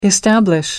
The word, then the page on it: establish: American English